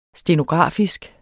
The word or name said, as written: stenografisk